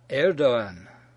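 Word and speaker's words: Erdoğan: The letter G in the Prime Minister's name is silent. It serves only to add a slight lengthening to the preceding vowel. So the guy's name is Erdogan.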